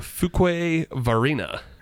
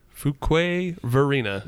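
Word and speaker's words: Fuquay-Varina: It is a Fuquay Varina. – Okay. – Yeah. Fuquay Varina, North Carolina.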